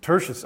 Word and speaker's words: Tertius: Tertius. Okay, thank you. I laughed because I said it. Tortillas. It sounded like a kind of like a Mexican food or something as I pronounced it, you know.